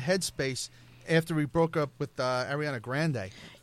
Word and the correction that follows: Grande: You say it like you're ordering her from Taco Bell. – Ariana, by the way. – What is it? – Grande. – It's Ariana Grande.